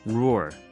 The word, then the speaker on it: roar: 그리고 앞서 들었던 곡이 어, 케이티 페리의 Roar 이게 우리나라에서는 으르렁 이렇게 얘기하지만 사실은 원 발음은 이 roar 이게 더 가까운 것 같아요.